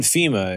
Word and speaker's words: Fhima: It's spelled with an H, right? FHIMA. – Yeah. You got it.